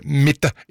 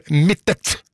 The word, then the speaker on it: Mittet: og navnet har alltid blitt uttalt med en tydelig T til slutt, akkurat slik det skrives. Derfor er det ganske frustrerende å høre Mitte i stedet for Mittet.